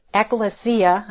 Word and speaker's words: ekklesia: It's ekklesia. I think I said ecclesia or something like that.